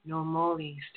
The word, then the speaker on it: Nomoli: And today's show, I'm so excited about because we get to get a rare peek into Nomoli Stones. Hey, did I say that right? Nomoli Stones. – Nomoli, yes.